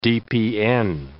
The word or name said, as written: DPN